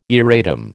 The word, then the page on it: erratum: American English